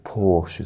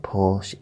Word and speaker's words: Porsche: It's not Porsche, it's Porsche. No, it's Porsche. In this country, it's called a Porsche. But some people that are really into cars, they pronounce it Porsche. And other countries pronounce it Porsche, but England, we call it a Porsche.